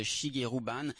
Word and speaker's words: Shigeru Ban: Et voilà, donc le nom de cet architecte s'appelle à peu près comme il se prononce, Shigeru Ban, Shigeru, espace Ban.